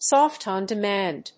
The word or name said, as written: Soft On Demand